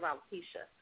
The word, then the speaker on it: alopecia: Now, I want to know about this term that I've come across, and I hope I'm pronouncing this correctly, alopecia. Would you tell us how that's connected with hair and health? A-L-O-P-E-C-I-A. Pronounce it for me. Say it again.